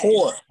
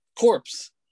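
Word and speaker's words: corps: Core. Like, core. – What did I say? – Corpse.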